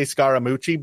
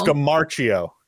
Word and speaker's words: Scamarcio: Yeah, it's a car racing movie starring Daniel Brühl and Ricardo Scamarcio. I always want to say Scaramucci, but that's not right. – No. – Scamarcio, I believe is how you say his last name.